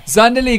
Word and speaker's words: Zandelee: Zandelee, good morning.